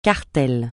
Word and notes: cartel: francais

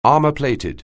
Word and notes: armour-plated: anglais

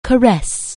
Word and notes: caress: anglais

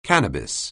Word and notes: cannabis: anglais

